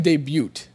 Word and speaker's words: debut: Stop saying debut. – Can you let me... – It's like baloney. There's a silent letter.